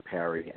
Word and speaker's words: Pari: They look for my name, Andrew Parry, and it's spelled P-A-R-I, like Paris, France, without the S, and then Sexual Assault Awareness.